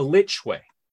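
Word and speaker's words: lych way: L-Y-K-E. What is a like way? – A lich way. – Oh, it's pronounced lich. Okay.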